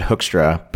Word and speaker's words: Hoekstra: You know, it actually, like, I think technically my last name, we pronounce it Hoekstra, but in Dutch, it's much different.